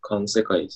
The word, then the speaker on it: 環世界: 生物たちが独自の知覚と行動とつく行動で作り出すこれ、環世界って読むんですかうん。環世界ですね。